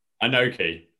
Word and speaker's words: Inoki: So Bret Hart was trained by, pronounce it again for me, Inoki? – Inoki.